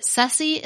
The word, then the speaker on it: surcie: She said that sussy is an alternate pronunciation of Cersei, which is a commonly used word in South Carolina to describe a just-because gift or a thoughtful surprise. It's usually spelled S-U-R-C-I-E, and she even had a friend who named her dog Cersei because the dog was a delightful but unexpected gift.